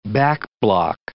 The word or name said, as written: backblock